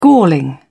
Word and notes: galling: British English

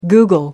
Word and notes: google: American English

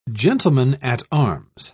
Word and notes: gentleman-at-arms: British English

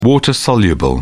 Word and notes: water soluble: British English

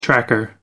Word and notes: tracker: American English

